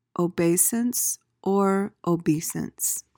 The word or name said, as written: obeisance